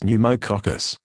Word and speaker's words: pneumococcus: Pneumovax 23 is a vaccine given to help prevent infections caused by certain types of germs or bacteria called pneumococcus, pronounced pneumococcus.